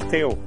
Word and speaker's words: Theo: H-E-N-N-I. Sorry. – That's okay. And what's your name? – My name is Theo. – T-H-E-O.